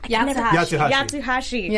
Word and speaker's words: Yatsuhashi: But so we get this, we get a fight scene with two members from Team Coffee, Coco and Yatsuhashi.